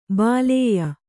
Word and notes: ಬಾಲೇಯ: Kannada

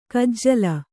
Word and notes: ಕಜ್ಜಲ: kannada; kajjala

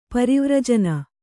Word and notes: ಪರಿವರ್ಜನ: parivarjana

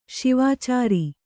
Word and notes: ಸುವಿಚಾರ: suvicāra

